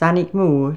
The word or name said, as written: Sanaigmore